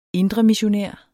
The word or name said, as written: indremissionær